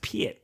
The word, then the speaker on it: Pit: It's pronounced Pit.